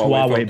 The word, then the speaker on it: Huawei: It's pronounced Huawei, but it...